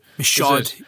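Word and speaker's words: Michôd: Michaud, yeah.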